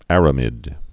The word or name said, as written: aramid fiber